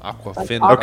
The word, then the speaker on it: Awkwafina: Awkwafina. – Awkwafina. – Okay, yeah, awkward, but with Awkwafina.